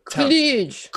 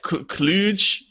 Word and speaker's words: Cluj: Cluj. – Cluj, Romania.